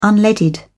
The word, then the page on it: unleaded: British English